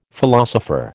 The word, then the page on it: philosopher: American English